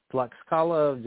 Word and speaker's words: Tlaxcala: Tlaxcala. Tlaxcala, just for anybody who's listening, how you spell it is T-L-A-X-C-A-L-A, Tlaxcala.